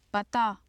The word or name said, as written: pata